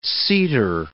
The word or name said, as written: seater